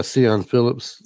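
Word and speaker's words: Siân Phillips: How would you pronounce that? It's got the little thing over the A. Cian Phillips. – Yeah, Cian Phillips.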